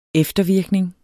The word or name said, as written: eftervirkning